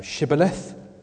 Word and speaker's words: Shibboleth: There's this word that you can see there in chapter 12, Shibboleth, which means torrents or grain, and Sibboleth, which means grain. So Shibboleth or Sibboleth. Ephraim can't pronounce sh, so they can't say Shibboleth, they say Sibboleth.